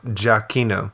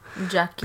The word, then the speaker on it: Giacchino: And surprisingly, John Williams, I don't think he did the music because it was Michael Giacchino. – Giac. How do you say it? Giac. No, Giacchino. – Giacchino. – Because I forgot. I used to study Italian, so you pronounce it Giacchino.